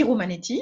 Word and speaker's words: Romanetti: Euh, Sylvie Romanetti, hein, comme, quasiment comme ça se prononce.